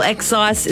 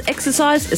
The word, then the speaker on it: excise: I also hope that you topped up with your petrol yesterday because, of course, the fuel excise. Is that how you say it? Excise? Exercise? It's not exercise.